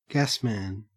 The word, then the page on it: gasman: Australian English